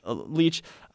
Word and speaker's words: Leitch: I think it's Leitch. – Leitch.